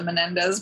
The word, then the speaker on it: Menendez: Is it just a documentary about the Menendez brothers? – Probably. Oh, you said it right, and yes, it probably is that.